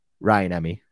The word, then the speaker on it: Rajaniemi: Alex Rajaniemi, I think it's pronounced. – Ryan Emmy. – But he goes by Raj. – Because yeah. The J is silent when you say his full name.